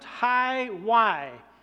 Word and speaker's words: HIWI: It's the letters H-I-W-I, but it's pronounced Hi-Y.